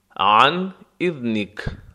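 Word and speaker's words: عن إذنك: با اجازه، عن اذنک، عن اذنک. عن عین حرف حلقی نرم و غلیظ، عن، عن اذنک. زا هم اینجا دالزال می باشد و نک زبانی تلفظ می شود.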